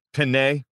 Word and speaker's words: Penei: Penei.